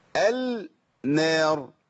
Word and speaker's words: النار: أما أقول النار بنطقها بقول إيه؟ بقول النار ولا بقول النار؟ صح؟ يبقى بدخل اللام في النون بقت إيه؟ النار.